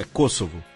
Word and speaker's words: Kosovo: É Kosovo? – Kosovo. – Pronunciei direito.